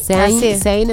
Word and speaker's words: Zayn: Es Zayn. Zayn en los 40. – Uh-huh. – Zayn en los 40.